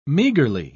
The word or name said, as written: meagerly